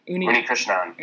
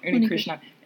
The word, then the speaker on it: Unnikrishnan: Deepak Unnikrishnan. – Unnikrishnan.